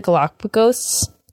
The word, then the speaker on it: Galapagos: Wants to travel to the Galapagos.